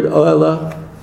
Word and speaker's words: ohela: Saraima, you know that word ohela? The accent is on the pre-penultimate.